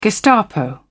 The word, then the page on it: gestapo: British English